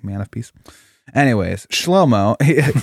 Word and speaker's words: Shlomo: Solomon's name in Hebrew is pronounced Shlomo. I think Shlomo. It's fun. Shlomo. – That is fun. – Yeah. Shlomo. Shlomo. It's from the root that we get the word shalom.